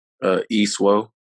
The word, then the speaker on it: Isuo: Isuo.